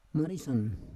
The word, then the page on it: marisan: Roon